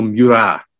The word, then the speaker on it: Murat: Or Marshal Murat, as we should call him, to do the French pronunciation.